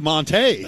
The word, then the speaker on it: Montague: You know, Scott, I had a laugh earlier this week because they didn't have a pronunciation guide for Jonathan Montague because they don't know how we roll down here in Texas, do they? – I don't think they do. – I looked at that and said, well, that's Montague.